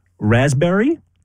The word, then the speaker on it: raspberry: Raspberry is the silent S. – P. – Or L-P. – Silent P, right?